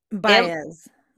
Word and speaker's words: Baez: Baez.